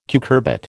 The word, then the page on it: cucurbit: American English